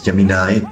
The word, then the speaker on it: Gemini: En inglés es Gemini, se escribe Gemini con G de gato, así como suena,